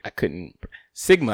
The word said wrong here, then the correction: smegma: I think, is the way you pronounce it. – Sigma. – It's S-M-E-G. – Smegma. – Smegma. Okay.